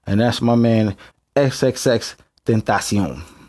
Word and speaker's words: XXXTentacion: That's how you say his name for anybody who don't know. I ain't gonna lie. When I saw his name, I thought it was extension. You feel what I'm saying? But it's XXXTentacion.